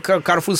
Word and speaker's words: Корфу: Карфу, а не Корфу, как оказывается, правильно говорить.